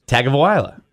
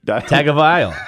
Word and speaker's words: Tagovailoa: Yeah, I mean, I think you're close to pronouncing that right. I'm not sure. – You think I'm close on calling it for a bust? – No, the last name pronunciation. – Tagovailoa. Tagovailoa. I mean, you got it, man.